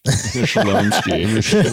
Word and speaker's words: Chlumsky: Anna Chlumsky. – Anna Shalomsky. – Shalomsky. – That's the proper pronunciation.